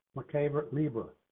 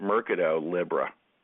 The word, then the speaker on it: Mercado Libre: MELI, Mercado Libre. MELI. – And Mercado Libre. – Yeah. – Right. Yeah, I had a broker actually call it Mercado Libre.